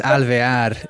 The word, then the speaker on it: Alvear: Yeah, it's Alvear in Espanol.